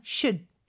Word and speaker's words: should: 그리고 should는요. 그냥 should. should 이렇게 짧게 발음되는 경우도 많아요. L 발음 절대 하면 안 되고요.